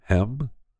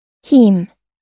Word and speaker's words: heme: The nuggets do not contain HEME, which is produced genetically modified yeast. All right, I got to see how you pronounce it. – Heme.